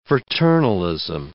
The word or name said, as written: fraternalism